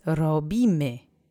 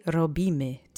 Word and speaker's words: robimy: Let's say robimy together three times. Accent is on the middle syllable. Try to bounce on that R if you can. Robimy.